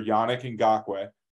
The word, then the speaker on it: Yannick Ngakoue: There's a rumor they're going after Yannick Ngakoue, or however you pronounce his name.